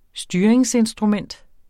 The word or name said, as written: styringsinstrument